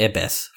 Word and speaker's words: ibis: Sebastian's the ibis for University of Miami. What's an ibis? Do you know that bird? – The ibis? – Ibis. Oh, that's how you pronounce it? – Yeah.